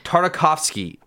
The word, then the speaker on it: Tartakovsky: Tartakovsky.